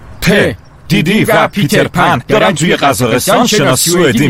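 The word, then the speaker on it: Peter Pan: وقتی میخوای بگی پیتر پن باید پهاشو درست تلفظ کنی، بگو تا دهنت عادت کنه. – چطوری؟ – په پیتر پن، تکرار کن ببینم.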